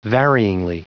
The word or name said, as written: varyingly